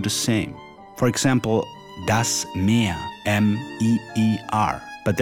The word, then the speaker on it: mehr: Das Meer is the ocean, and Meer, M-E-H-R, translates to more. These two homonyms both have the long E sound.